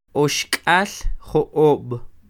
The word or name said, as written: Oxk'al jo'ob